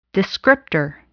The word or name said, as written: descriptor